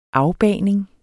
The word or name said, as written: afbagning